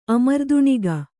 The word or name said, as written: ಅಮರ್ದುಣಿಗ